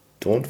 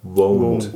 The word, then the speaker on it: Won't: Don't forget oder? – Won't. – Won't.